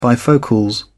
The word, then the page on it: bifocals: British English